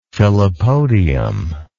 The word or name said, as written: filopodium